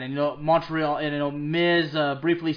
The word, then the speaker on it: Montreal: Yeah, I gotta say too, I'm French-American, so I gotta say it correctly. It's Montreal, ladies and gentlemen.